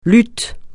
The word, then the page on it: luth: francais